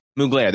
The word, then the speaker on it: Mugler: It's giving you sort of how do you pronounce that? He just passed. – Mugler. – Mugler, there you go.